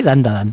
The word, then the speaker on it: Dandadan: Tenemos Dandadan Dandadan, creo que lo he dicho bien, Dandadan. – Sí, Dandadan.